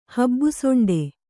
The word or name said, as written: ಹಬ್ಬುಸೊಂಡೆ